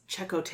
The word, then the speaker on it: Chakotay: Excuse me, it's Chakotay. – Oh yeah, it's Chakotay. – Thank you. – They say his name with an accent in this version.